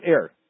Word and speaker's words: heir: Okay, as his heir. The H is silent.